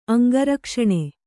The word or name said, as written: ಅಂಗರಕ್ಷಣೆ